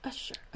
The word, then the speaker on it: Usher: Okay, so the director, Kinga Usher, I think that's how you say his name. – Mm-hmm. Usher.